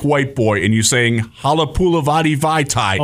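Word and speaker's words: Halapoulivaati Vaitai: Yeah, so we got bad news on Halapula Vadi Vaitai. Hey, I said it right first time.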